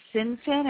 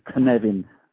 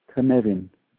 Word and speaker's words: Cynefin: Well, in Welsh, the C is always hard. It's like the K. It's a Cynefin, how you say it, yeah. – Say that again. – Cynefin.